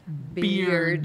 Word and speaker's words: beard: Ok, bird. Beard, pero se pronuncia beard.